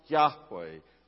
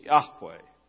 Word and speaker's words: Yahweh: But for now, the personal name of God is the Tetragrammaton, YHWH, and we believe that that term is pronounced Yahweh, Lord, the name of God. Every time in these verses that Jonah calls on the Lord, he calls on Yahweh. It is not Adonai, it is Yahweh.